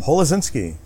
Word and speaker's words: Holewczynski: Holozinski. – Holozinski. – It's so easy. I'm not the only one with a last name that people can't pronounce.